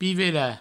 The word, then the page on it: pivelar: pivela